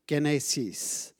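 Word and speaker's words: genesis (γένεσις): Birth in Greek is this word up here. And you pronounce it genesis.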